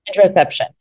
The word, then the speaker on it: interoception: You talk about, and I might say it wrong, interoception? – Interoception, yeah. – And I totally said it wrong.